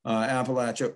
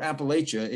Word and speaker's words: Appalachia: There's a lot of different attitudes about Appalachia or Appalachia if you grew up in New York as I did, especially how you pronounce it in the first place.